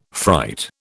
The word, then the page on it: fright: British English